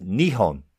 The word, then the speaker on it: Nihon: The Japanese name Nippon is now pronounced Nihon in modern Japan.